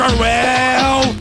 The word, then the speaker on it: Orwell: É Orwell? Eu não sei pronunciar Orwell. o sobrenome. – Orwell.